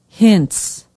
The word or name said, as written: hintz